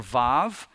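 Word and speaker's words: Vav (וו): Then the little line that almost looks like a seven or something, that's the Hebrew letter Vav. In modern Hebrew, it's pronounced with a V. In ancient Hebrew, it was pronounced with a W.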